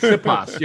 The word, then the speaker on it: Sipos: Sipos. – Oh, so you're not much better than I am. – Yeah, it's Sipos.